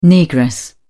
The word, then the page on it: negress: British English